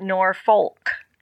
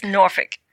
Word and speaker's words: Norfolk: He's not from Norfolk, because that's not how you say Norfolk. It's not Norfolk. – You know, it's how it's spelled. But you've been around there for that long. – Yeah, only people not from there would say Norfolk. – Right. – It's Norfolk.